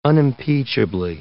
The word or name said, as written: unimpeachably